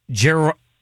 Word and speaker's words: gerontocracy: Who rules in a gerontocracy? – Gerontocracy.